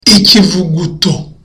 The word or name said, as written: ikivuguto